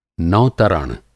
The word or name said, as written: नौ-तरण